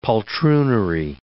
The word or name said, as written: poltroonery